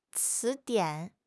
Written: ci2 dian3

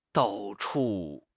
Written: dao4 chu4

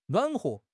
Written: nuan3 huo5

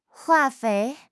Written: 化肥

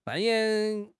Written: 扮演

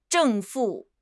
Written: 正负

